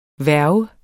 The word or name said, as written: hverv